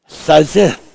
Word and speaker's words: tsiytsith: It gives this, and it is spelled T-S-I-Y-T-S-I-T-H. Sizith. Now, interestingly, I have been doing these teachings on the Zith. Z-Z-I-T-H, if we're using American English.